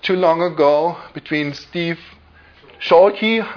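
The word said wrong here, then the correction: Chalke: or how do you Chalk? – Yeah, he is very how do you pronounce him? Chalk? Steve Chalk.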